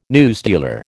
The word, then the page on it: newsdealer: American English